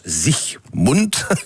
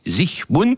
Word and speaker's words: Siegmund: Alors, l'homme fort qui a permis cette victoire, c'est Ulrich Siegmund, en allemand ça se prononce Siegmund. Pas d'alga, j'ai pas dit Sieg Heil, j'ai dit Siegmund.